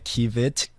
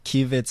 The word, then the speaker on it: Kiviet: I'm on Facebook, Yamkela Kivit. Kivit sounds like a very Western surname. – You are a Klink Afrikaans. – Let me spell it. K-I-V-I-E-T. – Yeah. – I'm on Instagram, Yamkela Kivit, same thing.